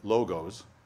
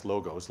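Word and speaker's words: logos (λόγος): We would probably pronounce it logos, L-O-G-O-S, logos, like the logo on your shirt or the logo on your car. But the Greeks wouldn't pronounce it that way. They'd say logos.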